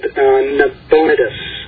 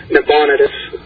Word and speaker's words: Nabonidus: And basically, the Bible confuses Nebuchadnezzar with this other king called Nabonidus. Forgive me for not pronouncing the name correctly, but I think it's pronounced as Nabonidus.